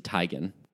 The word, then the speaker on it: Teigen: She came up in that conversation because her name is actually pronounced Chrissy Teigen. – Oh, which makes more sense because she has that Nordic background. – So it's pronounced Chrissy Teigen, but she even said that, like, she has fallen into correcting people to say Chrissy Teigen because it's been mispronounced so much.